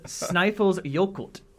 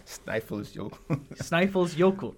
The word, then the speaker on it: Snæfellsjökull: It is Snæfellsjökull. – Snæfellsjökull.